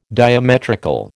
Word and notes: diametrical: American English

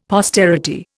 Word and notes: posterity: Indian

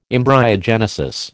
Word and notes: embryogenesis: American English

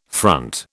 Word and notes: front: British English

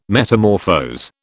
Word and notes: metamorphose: British English

